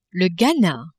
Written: Ghana

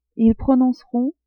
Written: ils prononceront

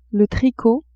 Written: tricot

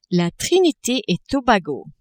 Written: Trinite-et-Tobago

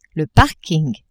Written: parking

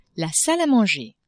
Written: salle a manger

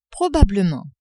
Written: probablement

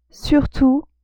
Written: surtout

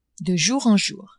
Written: de jour en jour

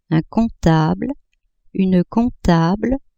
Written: comptable-comptable

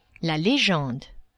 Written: legende

